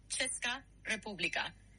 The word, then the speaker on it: Česká republika: Y se pronuncia aproximadamente Česká republika. Detalles. Ches suena como ch de chico. Česká, Česká. República, República, con el acento principal en la primera sílaba, aunque para un hispanohablante suena natural decir República.